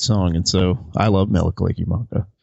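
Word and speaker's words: Mele Kalikimaka: How do you say that? – Yeah, Mela Kaliki Maka. That is the Hawaiian version of Merry Christmas.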